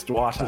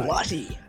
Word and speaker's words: Duarte: You will see I have written one name in bold, and that man is Chris Duarte, or Chris Duarte, however you pronounce it. I think it's Duarte. – Duarte.